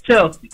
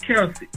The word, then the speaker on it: Kelsey: You had said her name wrong. You said Chelsea. Like it's Kelsey.